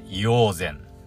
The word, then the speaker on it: 医王山: に、山と書いて、硫黄膳ですね。この、山と書いて、善と読む、読ませるの珍しいですよね。